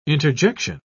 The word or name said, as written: interjection